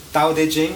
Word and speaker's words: Tao De Jing: but it's something closer to Tao Chinese pronounce it different to Japanese. How would a Chinese person pronounce this book? Tao De Jing, okay.